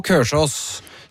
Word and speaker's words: Kolsås: Vi godt inne I september, og vi på Kørsås, som vi lærte at hva den traditionelle måten å uttale Kolsås på.